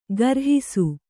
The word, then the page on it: ಗರ್ಹಿಸು: garhisu